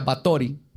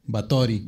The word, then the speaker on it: Báthory: Pues de hecho, investigando este caso, descubrí que se pronuncia Batori. Y así le voy a decir porque está padre. Batori.